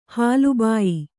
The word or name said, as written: ಹಾಲುಬಾಯಿ